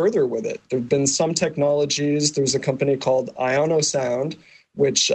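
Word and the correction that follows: Iosono: Eosono, I think, is what you're thinking. – I'm sorry, I mispronounced it. Eosono,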